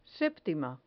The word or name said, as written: septima